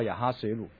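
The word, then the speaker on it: 亚哈随鲁: 但是你看到，因为西方哈，我们在欧美的话，他的那都是继承希腊人的文化，所以你只要读到欧美的历史的话，他讲到这个王一定是用这个希腊文的这个发音的，所以你就看不到亚哈水鲁。但是他实际的发音是比较近似亚哈水鲁哈。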